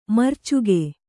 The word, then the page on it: ಮರೀಚಿಕೆ: marīcike